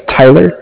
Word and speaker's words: Tyler: My name is Tyler, T-Y-L-E-R, and I am calling with Cardiff. It's spelled C-A-R-D-I-F-F, as in Frank Frank.